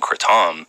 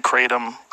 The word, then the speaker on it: kratom: Is that the right way to say it? Because people say Kratom. Yeah. You're the only one I've heard say Kratom. – It's a Thai word. People in Thailand call it Kratom.